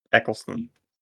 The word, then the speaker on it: Eccleston: Christopher Eccleston. – Yes. – Oh, look at that. You learned how to say it. – Question. – Eccleston.